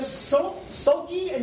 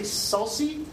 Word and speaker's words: sulci: We have sulci and gyri. You might hear someone say sulci, but it's Latin, and all C's are hard C's. There's no soft C's in Latin.